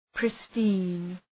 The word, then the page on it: pristine: ‘prıstın